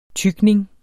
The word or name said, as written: tygning